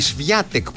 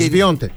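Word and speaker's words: Świątek: Η Σβιάτεκ που γράφεται. – Και... Σβιόντεκ.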